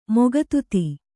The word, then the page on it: ಮುಗ್ಧತೆ: mugdhate